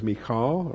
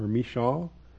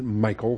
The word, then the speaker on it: Michal: The name of the firstborn was Merab, and the name of the younger was Michal or Mishal. Some people pronounce it Michael, but that's not right. Michal.